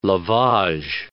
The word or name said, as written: lavage